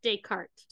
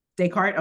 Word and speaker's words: Descartes: I think it's pronounced Descartes. – Descartes.